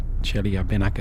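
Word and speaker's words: Chelyabinsk (Челябинск): And the greatest one is the Chelyabinsk meteor. Chelyabinsk, as they pronounce it, in Russia.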